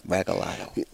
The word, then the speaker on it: Regalado: That's how you say the name, Regalado.